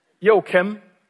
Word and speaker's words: Joakim: Well, as you heard, my name is Joakim, and I'm fully aware that that's a very hard name to pronounce in English. So, but let me just give you kind of an idea. Imagine a rapper would greet the North Korean dictator, Joakim.